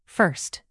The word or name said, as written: first